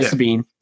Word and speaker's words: Sabine: Yeah, Sabine.